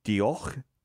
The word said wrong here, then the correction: Diolch: Jimmy? – Yeah. Really? Diolch. Yeah.